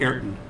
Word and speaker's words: Ayrton: So, and just to kind of talk about the story of it, so it's Ayrton Senna, is it Ayrton, how do you say his name? – Ayrton.